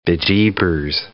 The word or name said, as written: bejeebers